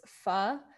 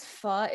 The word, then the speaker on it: pho: and it's pho.